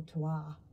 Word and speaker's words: terroir: they talk about terroir or terroir, if you're going to say it properly, which is like what the land and the weather and the sun brings to a particular harvest.